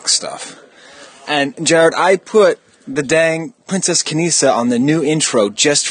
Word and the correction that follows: Kneesaa: Um, it's pronounced Kneesaa, but um, no, I do understand.